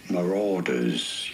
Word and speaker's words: marauders: In British English, this is normally pronounced as marauders. You do want to stress on the second syllable.